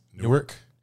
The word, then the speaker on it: Newark: Newark.